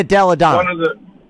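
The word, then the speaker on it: Delle Donne: So the American team, Sue Bird, Donna Taurasi, Belldonna, WNBA most valuable player in the last 2 years. – Yeah, Elena Delladonna.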